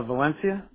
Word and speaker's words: Valencia: Valencia. – Okay, how do you spell it? – V-A-L-E-N-C-I-A.